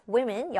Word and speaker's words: women: 발음도 살짝 바뀌어요. women이라고 합니다. women, 여자들이고요.